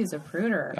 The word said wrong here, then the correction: Zapruder: I think you mispronounced it. – Oh, Zap. – Remember? Zapruder. – Oh, he put the emphasis on the wrong syllable.